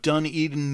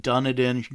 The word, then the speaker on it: Dunedin: Straight out of Dunedin, New Zealand, and all this time I have been pronouncing it Dunedin. Shame on me.